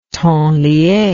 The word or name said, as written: temps lié